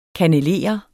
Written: KANNELER

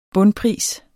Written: bundpris

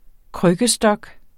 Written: krykkestok

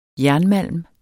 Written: JERNMALM

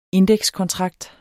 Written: indekskontrakt

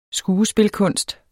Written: skuespilkunst

